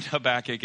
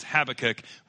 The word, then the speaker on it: Habakkuk: So it's not Habakkuk. It is Habakkuk.